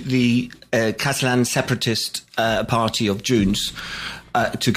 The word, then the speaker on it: Junts: Also looking at La Vanguardia, also has La Vanguardia and El Mundo also have these stories on Hunt. Am I pronouncing it correctly? Junts, right.